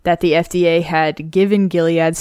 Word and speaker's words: Gilead: Next headline, we got Gilead. Is that how you say it? Gilead Sciences? – Yeah, you got it.